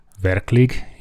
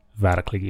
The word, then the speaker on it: Verklig: I'm a creative director and the co-founder of the design company called Verklig, if you pronounce it in a sort of Finnish way. You can also do it Verklig if you're Swedish, or if you're us, Verklig.